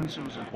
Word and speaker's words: openSUSE: And you said OpenSUSE. That's the official pronunciation? – Yeah, OpenSUSE.